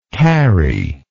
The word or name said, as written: carryable